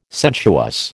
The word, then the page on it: sensuous: American English